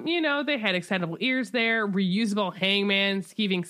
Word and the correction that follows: Skiving: snack boxes, except I think we pronounce them skiving snack boxes.